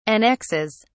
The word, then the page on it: annexes: British English